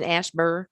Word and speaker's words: Asheboro: And, you know, living in Asheboro, North Carolina.